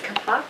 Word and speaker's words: kapok: I had never heard of, how do you pronounce this, kapok? – Kapok.